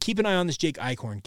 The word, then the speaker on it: Eichhorn: is that officially Jake Eichhorn, that's how you actually pronounce his name correctly. I said that incorrectly when he originally was had committed to BYU.